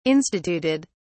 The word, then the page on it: instituted: British English